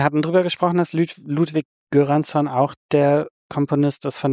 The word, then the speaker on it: Göransson: Wird wie J ausgesprochen, das G. – Göransson, ja.